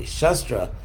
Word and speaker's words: shastra: Actually, not shastra, scripture, but with a short A. Shastra, because shast means to cut.